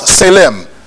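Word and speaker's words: tselem: It is spelled T-S-E-L-E-M. T-S-E-L-E-M. It is pronounced selem.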